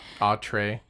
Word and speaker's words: atre: It's A-T-R-E. – Atre.